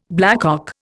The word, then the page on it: blackcock: Indian